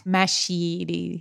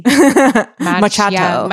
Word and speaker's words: macchiato: And also that I learned that macchiato is not a super intuitive word to pronounce. – Machiti. – Machiato.